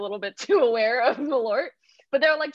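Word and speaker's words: Malört: Malort.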